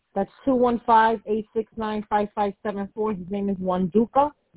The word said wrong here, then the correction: Wanduku: Wanduku. So yes. – Can you pronounce that for me so I don't mispronounce it for him? – You said it right. Wanduku.